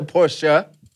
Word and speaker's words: Porsche: And if people remember, it's a Porsche. Not a Porsche. But a lot of people will say Porsche, but it's actually a Porsche. That's how you say it.